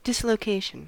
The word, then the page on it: dislocation: American English